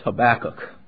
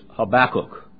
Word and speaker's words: Habakkuk: Habakkuk, notice the pronunciation of that. I'm mocked every time the preacher turns to Habakkuk. It's Habakkuk. Hebrew pronunciation is Habakkuk.